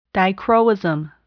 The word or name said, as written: dichroism